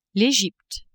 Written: Egypte

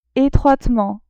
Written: etroitement

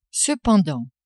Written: cependant

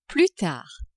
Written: plus tard

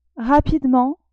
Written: rapidement

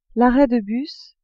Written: arret de bus

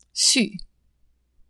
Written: sus

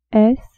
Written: s

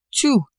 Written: tout

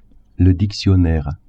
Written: dictionnaire

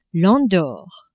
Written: Andorre

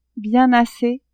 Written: bien assez

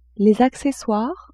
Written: accessoires

